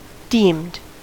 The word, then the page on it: deemed: American English